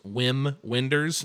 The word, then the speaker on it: Wim Wenders: And for anybody who wants to look up Wim Wenders, it is Wim Wenders. That's what it looks like, but I believe it's pronounced Wim Wenders.